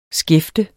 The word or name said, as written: skæfte